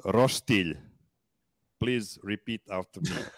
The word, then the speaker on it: roštilj: Roštilj. – First of all, you said phonetically and then you put a roštilj. G in there. That is not how you would pronounce this. Roštilj.